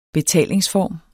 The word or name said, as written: BETALINGSFORM